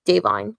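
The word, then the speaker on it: DaVine: that's actually how you pronounce it. It's not Devine, it's Devine, Joy Randolph, is literally sweeping everything, and she's so good in that movie.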